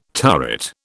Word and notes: turret: British English

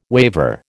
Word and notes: waiver: American English

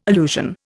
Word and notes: allusion: Indian

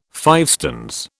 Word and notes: fivestones: British English

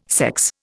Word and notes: sex: Indian

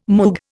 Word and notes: Moog: Indian

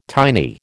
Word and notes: tiny: American English

